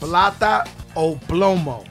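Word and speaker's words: Plata o Plomo: How do you pronounce it again? – It's Plata Oblomo.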